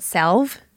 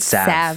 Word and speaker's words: salve: Did you call it salve? – I did. – It's salve. – Oh yeah, I think I've mispronounced that my whole life.